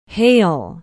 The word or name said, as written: hale